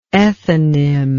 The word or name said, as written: ethanim